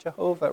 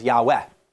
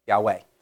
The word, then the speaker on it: Yahweh: And so in our hymn book, you know, guide me, oh, thou great Jehovah, right? And but recently, you know, many commentators would give the correct pronunciation as Yahweh, Yahweh.